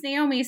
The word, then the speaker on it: Naomi: My name's Naomi, so they would, my mom would yell out like Naomi, like she couldn't do the last syllable.